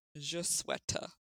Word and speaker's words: je souhaite: Okay, I think it is pronounced je souhaite, which just sounds like sweater, but apparently it means I wish in French.